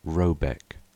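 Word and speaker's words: Råbäck: The manor house, or herrgård, in question is to be called R.A.B. A.E.C.K., pronounced something like Robeck, though that is not its name.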